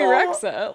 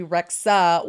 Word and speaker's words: Rexha: I think it's just Bibi Rexha. I think it's like a silent H.